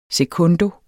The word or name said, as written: sekundo